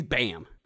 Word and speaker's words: Bam: It's spelled Bam, like Bam Margera. So he's Bam. – Yeah, but with an elongated ah.